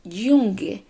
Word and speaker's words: Junge: Aunque se escribe junge, nosotros lo pronunciamos junge, porque ese sonido de la J es más o menos para que lo puedas comparar con el sonido de la Y en español.